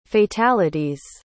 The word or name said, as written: fatalities